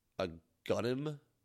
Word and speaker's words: Agunim: The RNG department boss, which the name is pronounced Agunim, A-G-U-N-I-M.